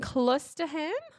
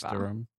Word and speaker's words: Cloisterham: Back in Cloisterham? – I mean, if it's in Britain, delicious. It wouldn't pronounce the H. – Whatever. Cloisterham.